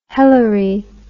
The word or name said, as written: hellery